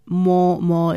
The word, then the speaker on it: Mormor: There is Canadian producer who goes by the name More More. It's spelled M-O-R-M-O-R.